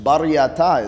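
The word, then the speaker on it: Baraita: Beraita is taught, but it's not the chosen passages. That's its language. Bar Yata is the real pronunciation. Bar means outside.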